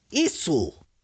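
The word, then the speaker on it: isu (椅子): Se dice isu.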